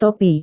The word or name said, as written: topi